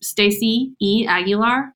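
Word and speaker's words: Stacye Aguilar: So I'm most active on my Instagram, which is Stacey E. Aguilar. And you check out the link because everyone spells my name wrong, but it's S-T-A-C-Y-E and then Aguilar, A-G-U-I-L-A-R.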